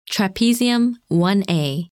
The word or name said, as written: Trapezium 1A